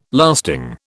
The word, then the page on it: lasting: British English